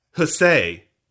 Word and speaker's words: huzzah: So it was actually pronounced in the, say, 1700s as hussay or hussay, more with a say, a, play kind of day.